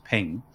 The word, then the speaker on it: PNG: Maybe it was a PNG, which I believe is pronounced ping.